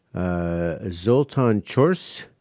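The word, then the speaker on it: Zoltan Csörsz: Zoltan Chors is how you pronounce that.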